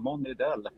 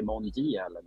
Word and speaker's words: Monidelle: Det, det ska uttalas som det stavas. Liksom. Monidell. Många brukar liksom byta plats på i och d där, så det är Monidell.